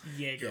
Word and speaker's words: Jaeger: It has a J in front of it, but it's pronounced Jaeger with a Y.